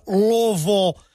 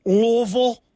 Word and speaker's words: Louisville: I'm saying that right, Louisville? – Louisville? Huh. It really doesn't matter, man. If you're from here, though, you pretty much drop the I and the S part of it, though, in the Louis.